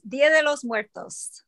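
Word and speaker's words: Día de los Muertos: She is going to talk to us about Day of the Dead, and would you please go ahead and say it so that the right pronunciation comes out? yes, it is Dia de los Muertos.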